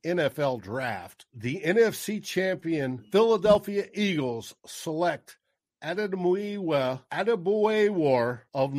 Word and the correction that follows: Adebawore: KT Smith, are we even close with the pronunciation? – So Adebawore, not bad at all. Adebawore. Well, he's an interesting guy.